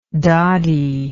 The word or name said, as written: doddie